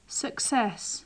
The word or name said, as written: success